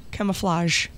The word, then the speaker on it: camouflage: Camouflage. – Come, I said camouflage. – It's French. – Camouflage. – Okay. Okay. Camouflage. – Uh-huh. Like a soft G.